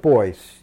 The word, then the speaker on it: Beuys: Joseph Beuys. He's a very interesting German artist. – Buys.